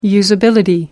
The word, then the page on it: usability: American English